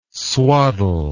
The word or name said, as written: swaddle